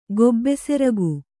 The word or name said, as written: ಗೊಬ್ಬೆಸೆರಗು